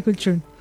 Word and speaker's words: cultrún: Me encanta cómo dice cultrún. – Porque así se pronuncia.